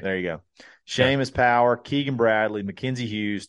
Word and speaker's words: Seamus: It looks like Seamus. I'm pretty sure it's pronounced. – It is Seamus.